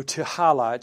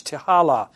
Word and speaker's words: Tehillah: I think it's pronounced in Hebrew, Tehillah. It's T'HILLAH, Tehillah.